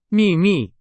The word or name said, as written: 秘密